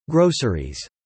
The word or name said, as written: groceries